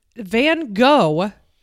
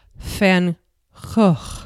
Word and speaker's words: Van Gogh: Van Gogh is, if I was to pronounce it correctly, in Dutch it would be Van Gogh, right? And if it was in French, I would say Van Gogh.